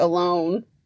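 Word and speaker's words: Ohlone: It's O-H-L-O-N-E, so like alone. Not alone.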